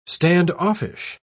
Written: standoffish

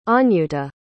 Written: anyuta

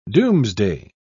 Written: doomsday

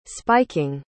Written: spiking

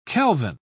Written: kelvin

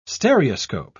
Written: stereoscope

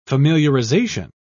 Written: familiarization

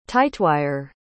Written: tightwire

